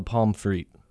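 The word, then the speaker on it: pommes frites: How do you pronounce that word with fries? You don't call them French fries. – No, they're called pomme frites.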